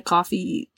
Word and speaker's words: Ko-fi: I do need to go back to the art store we have on Coffee. I think that's how you say it, because I think it's like a buy a coffee type website or whatever. So I think you pronounce it Coffee. But I need to make a listing. – K-O-F-I.